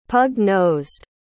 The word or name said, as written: pug-nosed